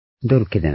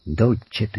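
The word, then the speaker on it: dolce: ちょっと、イタリア語では C はチッチャチチョと読むわけですね。ドルケでなくドルチェと言います。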